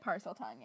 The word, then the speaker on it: Parseltongue: I want to talk about Parseltongue, or is that how you say it? – Parseltongue, yeah.